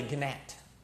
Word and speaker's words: gnat: So, you know, we'll give him some of his props, but he's like a gnat. The G is silent.